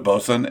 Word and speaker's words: boatswain: It's spelled Boatswain, but that's like a deck boss on a boat. But you just call it a bosun, and the beer looks really good.